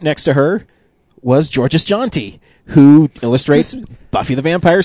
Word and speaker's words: Georges Jeanty: And so We talked to George's, and we found out his name is pronounced George's Jeanty, which we are always really concerned about.